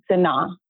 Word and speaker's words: Senat: And my last name, Sanaa. So it's Haitian. So it's got a silent T hiding out there. So I tell everybody, spell it like Senate of the House and take the E off. So S-E-N-A-T, Yvonne Sanaa.